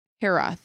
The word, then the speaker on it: hiraeth: It's pronounced Hirath. – How's it spelled? – Hirath. – H-I-R-A-E-T-H, pronounced Hirath.